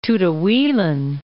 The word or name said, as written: Tutuilan